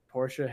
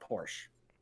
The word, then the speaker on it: Porsche: And it's Porsche, not Porsche. – Yeah, I used to have a shirt that said Porsche. It's a two-syllable word.